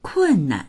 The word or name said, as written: kun4nan